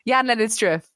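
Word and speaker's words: Jan-Lennard Struff: I never know quite how to say his name. Jan Lennard Struth? I don't know how to say his surname. Jan Lennard Struth. – That is correct. Yes.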